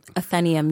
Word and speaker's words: Athenaeum: Is that how you pronounce it? – Athenaeum, yes. – I've been saying Athenaeum all these years. – You've had incorrect syllabulum phosphorus.